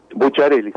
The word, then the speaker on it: Bucciarelli: Bucciarelli. – La doble C, sí, CCH.